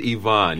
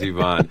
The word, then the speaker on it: Ivan: Or is it Ivan? Come on, help me out with the pronunciation here. – It is Ivan. Yes, it is Ivan.